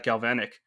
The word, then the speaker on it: Galvanick: But yeah, if you're happy to, let's dive right into what you're doing at Galvanic.